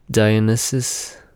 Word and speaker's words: Dionysus: Dionysus, Dionysus, yeah, he's sus as fuck.